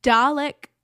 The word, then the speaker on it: Dalek: International Dalek Remembrance Day.